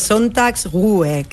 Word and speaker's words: Sonntagsruhe: El Sonntagsruhe, es una palabra difícil de pronunciar aún así, es Sonntagsruhe, que al final es descanso dominical, básicamente.